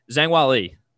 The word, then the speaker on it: Zhang Weili: I'm gonna butcher this one, Zhang Weili. – Yep, you did good.